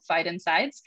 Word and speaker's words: phytoncides: And I found out that there is this chemical, this airborne chemical that plants produce, phytoncides. And I loved researching this. I loved learning how to say it also, phytoncides. But phyton means plant, inside means exterminate.